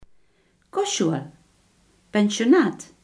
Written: kostschool

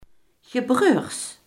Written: gebroeders

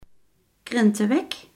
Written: krentenbrood

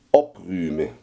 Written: opruimen

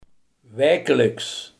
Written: wekelijks

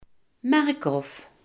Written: gaai

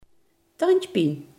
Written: kiespijn

